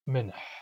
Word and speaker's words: minh: In New Chanoth, the counterpart to the English s is minh, which begins with a glottalized m, minh.